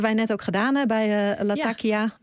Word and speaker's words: Latakia: In het gesprek over Israël sprak de correspondent de plaatsnamen uit als Latakia, terwijl jij Latakia zei.